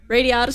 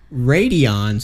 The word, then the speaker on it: Radiata: Radiata Stories? – Radeon Stories.